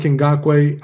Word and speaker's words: Ngakoue: You know, Yannick Ngakoue, obviously you don't want to trade from.